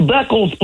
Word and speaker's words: Beckles: It's Beckles, Playboy.